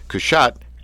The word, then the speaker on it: Cushatt: We are on the phone with Michele Cushatt. Did I say it right? – Pretty close. – Oh. Cushatt. – I said that, Cushatt, didn't I? – Like hat. Put on your hat. Cushatt.